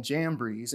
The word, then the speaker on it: Jambres: And then it ends with, Paul's buildup is, just as Jannes and Jambres, and yes, that's the proper way to say it,